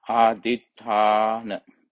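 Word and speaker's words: Adhiṭṭhāna: H-A, long A, N-A, Adhitthana.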